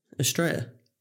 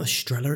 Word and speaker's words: Estrella: Estrella. – I call it an Estrella. I pronounce the L's. Is that... – Well, you'd be wrong. – Is that British of me? – I think so, yeah.